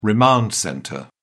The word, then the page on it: remand centre: British English